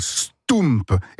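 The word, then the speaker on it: stoemp: Ça s'appelle un stoump. Et ça s'écrit S-T-O-E, parce que O-E en belge, ça fait où? – M-P. – Ah, un stoump.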